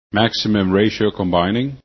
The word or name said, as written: MRC